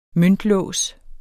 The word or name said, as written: møntlås